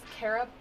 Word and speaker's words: Kara: Okay, so my name is Kara Bachman. It's K-A-R-A. B-A-C-H-M-A-N.